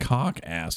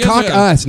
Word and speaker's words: caucus: Cock ass. That's so weird. – No, you pronounce it wrong. – Well, cock you guys us. Are just saying it fast. – It's cock us, not cock ass.